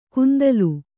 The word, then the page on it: ಕುಂತಲು: kannada